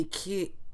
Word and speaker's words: Kyiv: he said if you're saying it in Ukrainian, you stress the first vowel and it's like an I in kid, so it'd be Kyiv. And then the second vowel of the second syllable is E. So it'd be like Kiev.